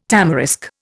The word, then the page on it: tamarisk: Indian